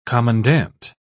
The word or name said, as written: commandant